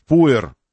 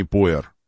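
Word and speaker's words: Poer: Правильно говорить Пуэр, а не Пуэр. Они почему-то Пуэр написали. Дизельный Пуэр.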